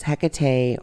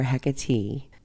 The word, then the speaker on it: Hecate: The Orphic mysteries claim that it was she, the winged mother Nyx, that gave birth to the goddess Hecate or Hecate, both of which pronunciations exist, just as her name can either be spelled with a C or a K.